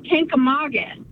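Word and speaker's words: Kancamagus: Apparently, I'm saying Kankamangus wrong. – I've been saying it wrong my whole life, I guess. – How do we say Kankamangus? – Kankamangus. There's no N in Kankamangus. It's Kankamagus.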